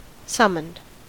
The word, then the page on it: summoned: American English